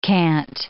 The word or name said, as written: cant